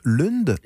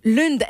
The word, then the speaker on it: Lund: En Suède, on prononce Lund. – Lund, exactement.